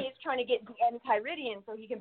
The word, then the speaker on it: Enchiridion: Endocrine, is that the proper pronunciation? It means like a manual, right? – Hang on, my buddy boy. Enchiridion.